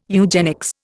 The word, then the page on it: eugenics: Indian